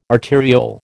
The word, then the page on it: arteriole: American English